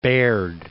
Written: Baird

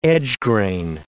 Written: edge-grain

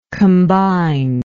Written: combinedness

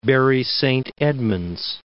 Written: Bury Saint Edmunds

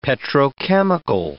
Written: petrochemical

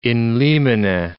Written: in limine

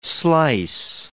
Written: slice

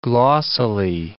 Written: glossily

